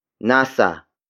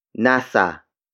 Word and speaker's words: nasa: Word for word, it's nasa, akin, ang, pitaka, mo. Nasa, akin, ang, pitaka, mo.